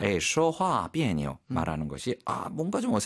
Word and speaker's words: 别扭: 비에뇨. – 비에뇨. 역시 그 니오자의 발음 경성으로 읽는다는 거 가볍게. 그래서 비에뇨.